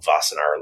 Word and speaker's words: Wassenaar: There's an international agreement called Wassenaar. It looks like Wassenaar, but the W is pronounced V.